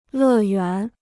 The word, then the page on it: 乐园: lè yuán